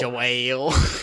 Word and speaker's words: Joel: Why did I say his name like that? JoJo. Go get a bucket. – Joel.